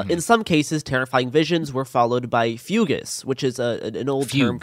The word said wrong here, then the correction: fugue: for, like, fugues. It says fugues. – Fugue. It's pronounced fugue. Yeah, it's like extreme disassociation.